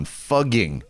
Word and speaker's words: Fugging: Fugging apparently reflects better the pronunciation of the town by the locals.